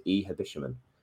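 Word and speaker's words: Ehibhatiomhan: I've actually been told that's how his name is pronounced, Ehabishman. So he started up front with Lucas Joao, and we played a 4-4-2, and it was just completely different to how it feels like we've been playing for the last 6 months.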